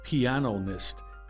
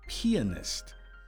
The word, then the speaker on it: pianist: It's not pianist, but pianist. We drop the O after the word piano and add the suffix ist to mean someone who plays the piano.